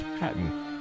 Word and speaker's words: Khatyn: Katyn, spelled K-A-T-Y-N, and Hatyn, spelled K-H-A-T-Y-N, which research tells us is pronounced Hatyn.